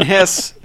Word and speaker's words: Hess: John Hess is a success. – Yeah. – Yes. – John Success. – It rhymes with success.